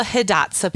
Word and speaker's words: Hidatsa: Now, I wanted to make sure, and I just went and looked it up because I wanted to make sure I said it properly. This is the Hidatsa people.